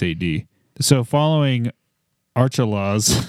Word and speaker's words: Archelaus: Is that Archilles? – A-R-C-H. – Because Santa Claus is spelled C-L-A-U-S. So this is actually Archelaus. Okay, we're going to go with Herod Archelaus.